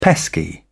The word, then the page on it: pesky: British English